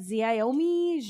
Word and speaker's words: Xiaomi: just so everyone knows, it's XIAOMI. I'm like Xiaomi. And how do you say it? – Xiaomi.